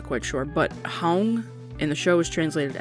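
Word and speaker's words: không: The next word is the word hong, and it is spelled K-H-O-N-G, and again, the O has the circumflex accent above it,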